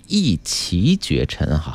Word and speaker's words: 一骑绝尘: 嗯，更正一个读音哈，刚才说到这半导体板块的时候是一骑绝尘哈，